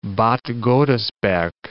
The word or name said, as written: Bad Godesberg